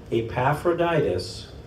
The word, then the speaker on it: Epaphroditus: Meanwhile, I thought I should send Epaphroditus.